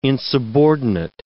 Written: insubordinate

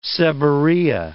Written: seborrhea

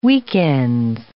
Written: weekends

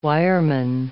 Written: wireman

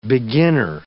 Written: beginner

